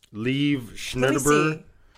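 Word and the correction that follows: Liev Schreiber: I cannot think of Lieve Schreiber.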